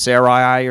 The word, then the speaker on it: Sarai: I know it's pronounced Sarai or some sort.